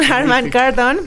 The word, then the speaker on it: Harman Kardon: Audio Premium Harman Kardon.